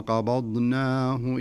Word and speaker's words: قبضناه: ساكنة، قبض والضاد متحركة، فلابد من قلقلة الباء، فلا نقول قبض قبضناه، لا، لابد أنك تقلقل عشان يخرج الحرف على طبيعته وعلى سليقته وعلى صحته. ثم قبضناه إلينا قبضا قبضا، قلقلة في الباء.